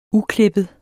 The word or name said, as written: uklippet